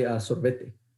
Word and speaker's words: sorbete: So in Ecuador, we say sorbete. – Oh, interesting. That sounds like sorbet, like the dessert.